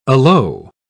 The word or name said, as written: alow